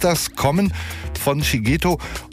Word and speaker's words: Shigeto: Ich sag nochmal seinen Namen klar, Shigeto heißt er.